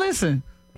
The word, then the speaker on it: listen: Listen is another one where the English words has a silent letter. – Silent and listen. – T. Listen. – Listen. – You don't hit no T in listen.